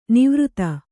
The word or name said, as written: ನಿವೃತ್ತ